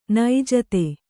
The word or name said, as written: ನೈಜತೆ